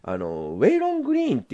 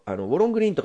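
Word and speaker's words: Waylon Green: で、なんでこんなになってんだかっていうとですね、監督がですね、この人ね、ウェイロン・グリーンっていう人、ウォロン・グリーンとか日本で書かれてますけど、本当の発音はウェイロン・グリーンだと思うんですけど、ウェイロン・グリーンっていう人は監督してるんですね。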